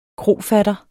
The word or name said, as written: KROFATTER